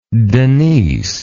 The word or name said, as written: Denise